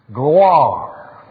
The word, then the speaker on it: gloire: Gloire. Now let's everybody say that now. Gloire. G-L-W.